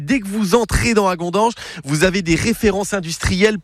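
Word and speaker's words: Hagondange: Au fil de la Moselle s'arrête cette semaine à Agondange. Je le dis bien. Non, je le dis mal, on dit Agondange avec un K et CHE à la fin.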